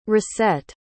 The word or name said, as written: racette